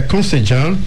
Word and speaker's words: concejal: Você pede para ele explicar o que é concejal e intendente. – Sim. – Concejal? É isso? É assim que você pronuncia? – Sim, concejal seria o vereador.